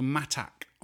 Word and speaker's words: mattak: In Greenland, they have a delicacy called matak It's spelled M-A-T-T-A-K, and it is raw whale skin and blubber.